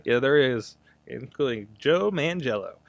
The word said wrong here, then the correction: Manganiello: I don't know if his name is Joe Mangello. – I can never say it right, so I just go with Mangello. – Manganiello.